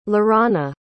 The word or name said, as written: laurana